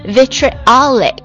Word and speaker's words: vitriolic: 好，我们来听一下这一单词的读音， vitriolic 两个短元音呢， vitriolic V-I-T-R-I-O-L-I-C vitriolic 刻薄的，恶意的，尖酸的，愤怒的。